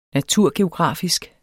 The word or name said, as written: naturgeografisk